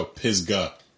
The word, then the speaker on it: Pisgah: No, Pisgah. P-I-S-G-A-H. – Pisgah. – What? Pis? – Pisgah. Pisgah. – What are you saying? – Pisgah? – There's no way you think that you're saying it right. – Pisgah. – No. – What are you saying? – There's no consonant. Look, you're putting a K sound.